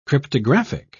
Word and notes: cryptographic: British English

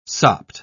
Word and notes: sopped: British English

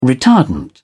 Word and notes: retardant: British English

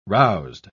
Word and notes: roused: British English

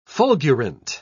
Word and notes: fulgurant: British English

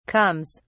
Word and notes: comes: British English

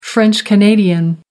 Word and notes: french canadian: American English

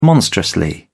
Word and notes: monstrously: British English

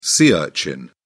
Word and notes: sea urchin: British English

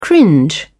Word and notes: cringe: British English

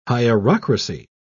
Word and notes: hierocracy: British English

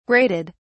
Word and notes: grated: British English

